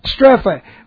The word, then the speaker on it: strepho: This one is meta, and now we have the word strepho, which looks like this. S-T-R-E-P-H, and then we have an O, strepho. Okay, this is M-E-T-A, and then S-T-R long E-P-H long O, metastrepho.